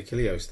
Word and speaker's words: Achilleos: What I will say about the Achilleos thing, I don't think any of the artists have been quite as influential as Achilleos. That whole pointillist effect. – Yeah, Achilleos, that's how you say it.